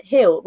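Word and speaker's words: Gil: We've got the new, um, I always say his name wrong as well. By I think it's Brian Hill rather than Gill.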